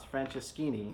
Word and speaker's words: Franceschini: And your last name's a hard one to pronounce, Franceschini. – Yeah, it's like Francesco with Ini on the end of it, Franceschini. Yeah. Without the O and Ini.